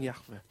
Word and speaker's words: Jahwe: Das ist nicht ein Wort, also ein Tetragramm, das kann man eigentlich nicht aussprechen, wenn man es liest, wenn man Hebräisch äh, gelernt hat. Ich habe es gelernt, Jahwe kann man nicht aussprechen. Wir sagen Jahwe.